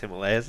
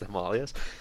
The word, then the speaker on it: Himalayas: It's not actually pronounced Himalayas, it's pronounced Himalayas.